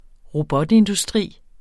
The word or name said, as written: robotindustri